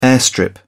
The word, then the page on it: airstrip: British English